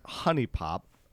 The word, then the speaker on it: HuniePop: So, uh, and it's actually pronounced honey pop. Um, fun fact. – Oh, really? Yeah. – Um, just thought I'd. It looks like Huni because it's spelled with a U, but it's actually pronounced honey.